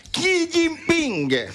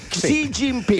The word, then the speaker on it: Xi Jinping: La pronuncia esatta è Xi Jinping. Xi Jinping, il presidente.